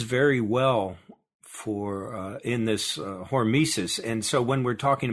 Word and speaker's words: hormesis: So the other thing is hormesis. Am I saying that right? – Yeah, hormesis, that's something we've talked about also.